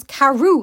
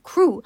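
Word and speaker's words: Crewe: Madame Pascal pronounced it as if it was Carew instead of Crew, but that might be merely a matter of pronunciation.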